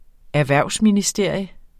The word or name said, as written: ERHVERVSMINISTERIUM